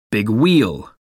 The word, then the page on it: big wheel: American English